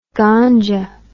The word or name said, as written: ghanja